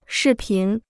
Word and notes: 视频: shì pín

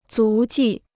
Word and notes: 足迹: zú jì

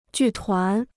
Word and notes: 剧团: jù tuán

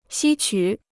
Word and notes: 吸取: xī qǔ

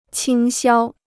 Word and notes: 倾销: qīng xiāo

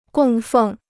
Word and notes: 供奉: gòng fèng

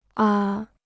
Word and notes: 啊: a

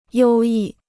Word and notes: 优异: yōu yì

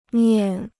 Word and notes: 念: niàn

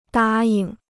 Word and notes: 答应: dā ying

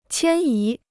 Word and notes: 迁移: qiān yí

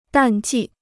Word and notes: 淡季: dàn jì

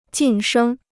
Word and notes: 晋升: jìn shēng

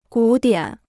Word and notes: 古典: gǔ diǎn

